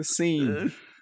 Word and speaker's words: Seine: S e i n e, the scene.